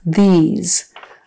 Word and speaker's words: these: These. So the voice TH is at the beginning. These. And it's a long E and a Z.